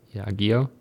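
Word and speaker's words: Yageo: Do we need to talk about Yagyo buying Kemet? I don't even know if that's how you say it. Yagyo? – Yago. – Yago? Yeah, sure. – That's how I say it.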